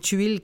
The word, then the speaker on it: Tuil: Claudia, je rappelle le titre, Les choses humaines de Karine Tuil. Comme ça se prononce, T-U-I-L, prix interallié, prix Goncourt des lycéens.